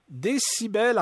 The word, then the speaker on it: DCBEL: En tout cas, retenez le nom Décibel. En fait, ça s'écrit D-C-B-E-L, comme DC en anglais.